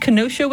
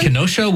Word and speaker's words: Kenosha: He's from, I think that's pronounced Kenosha, Wisconsin. – Kenosha, Wisconsin.